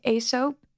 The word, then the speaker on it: Aesop: How do you say it? A soap? – Oh yeah. Yeah. Yeah. Yeah. I don't know how to pronounce it, but I know what you're talking about. – AESOP. – Yep.